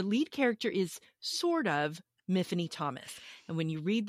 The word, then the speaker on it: Myfanwy: Now, let's get over the fact that her name is Myfanwy. It's a Welsh name. It rhymes with Tiffany.